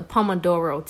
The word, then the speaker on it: Pomodoro: The Pomodoro technique. And it is spelled P-O-M-O-D-O-R-O.